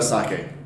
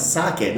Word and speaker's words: sake: Good selection of sake. – Yeah. – And for listeners, you may have noticed Brittany has been to Japan before, and she knows how to pronounce sake. Not sake, but sake. So good job on your Japanese pronunciation.